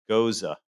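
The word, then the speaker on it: Gose: It's actually pronounced Goza. Like nose with an "a" at the end. – Still going to be ghost for me.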